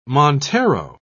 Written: montero

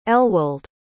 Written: elwold

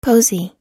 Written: posey

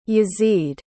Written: Yazid

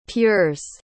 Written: pures

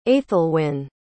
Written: aethelwyne

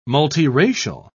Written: multiracial

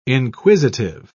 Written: inquisitive

